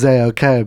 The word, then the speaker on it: Zayok: So you recently just dropped a mix with Zayok and Isabel. Did I pronounce that right? – I think you did. – Okay. – I think it's Zayok, but I personally, it's whatever you want it to be. It's Z-A-Y-O-K.